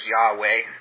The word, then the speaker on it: Yahweh: What we consider, we pronounce as Yahweh.